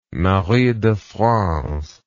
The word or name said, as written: Marie de France